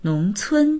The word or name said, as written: nong2cun1